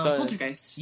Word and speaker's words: Poltergeist: I think the other one was, I always have trouble pronouncing it, but it's Poltergeist. You know which one I'm talking about. – Poltergeist. – Yes, there you go.